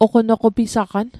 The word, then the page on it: oqonoqopisaqan: Mi'gmaq